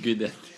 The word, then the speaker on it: Guidetti: Guidetti. – Det ska vara nästintill ett V i uttalet om jag inte missminner mig. Så att ett UI på italienska blir ett G.